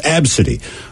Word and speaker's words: Abcde: And then you say it's pronounced Abcity.